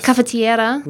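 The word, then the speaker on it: caffettiera: Cafetiera. – No. I'm certainly not going to try and pronounce it. No. – That's how my Italian friends say it. So that's how the ones I grew up with.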